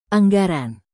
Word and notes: anggaran: indonesian

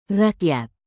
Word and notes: rakyat: indonesian

